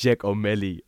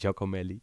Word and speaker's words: Giacomelli: De scheidsrechter Giacomelli, ik weet bijna niet meer hoe ik het moet uitspreken, want hij had een Facebook-account genaamd Giacomelli. Hij heet eigenlijk Giacomelli.